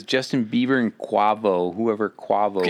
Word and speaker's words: Quavo: Quavo. – Quavo.